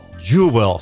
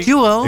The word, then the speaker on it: Jewel: Ik heb het over, jawel, Jewel, zo moet je het uitspreken. Sorry, Jewel.